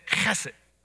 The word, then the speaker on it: chesed: And forgive me if y'all get sprayed, but chesed is spelled H-E-S-E-D, but the way you pronounce it is with a ch, you know? So you say chesed.